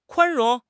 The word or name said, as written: 宽容